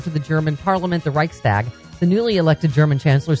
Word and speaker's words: Reichstag: Am I saying that right? Reichstag fire. – I believe so.